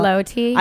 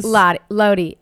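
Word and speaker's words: Lotte: Do you know how you pronounce the name of this hotel? It's L-O-T-T-E. It's like a fancy Midtown hotel. – Lottie. – I keep wanting to say it, but you know I'm very... I wasn't sure. I'm like very, very bad at pronouncing things. – Lottie. I think it's Lottie.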